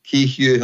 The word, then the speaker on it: Kehew: Yeah, Brian Kehew, yes. – Kehew, I wasn't sure how to pronounce that.